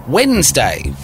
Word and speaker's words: Wednesday: Spell Wednesday. This is a tricky one. It's got like a silent letter in it. The correct spelling of Wednesday is Wednesday.